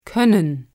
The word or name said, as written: koennen